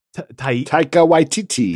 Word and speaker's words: Taika Waititi: I think, help me with the pronunciation again. – Taika Waititi. – Taika Waititi.